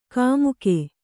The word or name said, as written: ಕುಮಕ್ಕು